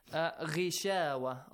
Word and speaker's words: غشاوة: غشاوة. غين is a heavy letter, but because it has a kasra sound, so light. – It has to be lightly pronounced.